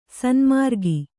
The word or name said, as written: ಸನ್ಮಾರ್ಗಿ